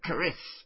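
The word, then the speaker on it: Charis (χάρις): The Greeks would pronounce it. Charis.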